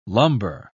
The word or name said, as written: lumber